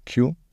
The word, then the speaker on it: queue: Q-U-E-U-E? – Ja. Yeah. – Das Witzige daran ist, dass dieser Buchstabe eigentlich ausgesprochen wird, wie eigentlich nur wie ein Q. Und die anderen 4 Buchstaben dahinter sind einfach nur silent.